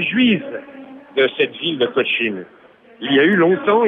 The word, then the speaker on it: Cochin: Ça s'écrit Cochin, comme l'hôpital Cochin.